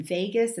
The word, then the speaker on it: vagus: And vagus is not like Las Vegas. It's spelled V-A-G-U-S.